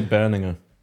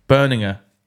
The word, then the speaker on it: Berninger: I would say Berninger. – Berninger.